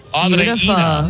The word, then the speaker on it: Andreina: Okay, now this person's name is spelled like Adriana, but her phonetics are Audrina. Is that right? Are you Audrina? Is that right? – It's Andreina. – Andreina.